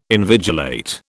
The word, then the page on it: invigilate: British English